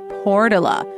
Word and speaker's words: Portola: And if you're not from around here, you might not know that that's how some locals pronounce it, Portola.